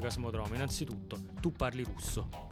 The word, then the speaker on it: Kosmodrom: Io sono figlia e nipote di sovieti o sovietici, non so come si dica ad oggi, e ho chiesto conferma alla mia famiglia su questa pronuncia, perché di me stessa mi fido poco, ed è Cosmodrome.